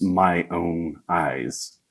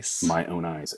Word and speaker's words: Mayonaise: And then somewhere along the years, I researched the meaning of it, and it's actually like a phonetic play. It's my own eyes instead of Mayonaise, the drop of the N. – My own eyes.